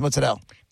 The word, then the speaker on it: mozzarella: And then they show you, be like, no, that's mutz. That's mozzarella. That's how it's pronounced mozzarella.